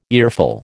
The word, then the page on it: earful: American English